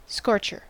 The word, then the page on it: scorcher: American English